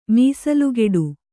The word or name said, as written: ಮೀಸಲುಕಟ್ಟು